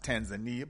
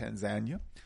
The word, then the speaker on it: Tanzania: And then Tanzania, which is, of course, many people pronounce it Tanzania, but it's pronounced Tanzania.